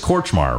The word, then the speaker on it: Kortchmar: you know, and for those that don't know, Danny, it's Kortchmar, right? Am I saying that right? – That's right.